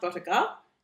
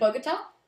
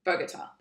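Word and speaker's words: Bogotá: I think it's pronounced Bogota, which is the capital city of Colombia. – Isn't it Bogota? – Bogota. That's it. Yeah.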